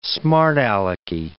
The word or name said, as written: smart-alecky